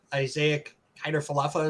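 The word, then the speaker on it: Isiah Kiner-Falefa: But IKF Isaac Kiner Falefa, is that how you say his name? – Yes.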